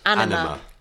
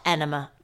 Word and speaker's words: Anima: Anima. – Which is spelled A-N-I-M-A. – Yeah. – Okay. – Not Enema.